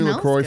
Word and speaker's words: LaCroix: LaCroix sparkling water, pronounced LaCroix, was developed to give health-conscious consumers refreshment flavor and a sparkle, blah, blah, blah, blah, blah.